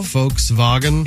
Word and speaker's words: Volkswagen: And I guess V is a F. – I guess so. – Volkswagen.